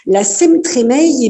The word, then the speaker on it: Semtremeil: Moi, il y a quelque chose déjà que j'aimerais savoir, c'est le nom de cette fête foraine, la Semtremeil, on prononce comme ça? – Eh oui, vous avez bien prononcé en ancien parler vosgien des Hautes-Vosges. La Semtremeil, eh bien, dans notre commune, ça veut dire la Saint-Barthélemy, puisque Barthélemy est le saint votif de notre commune.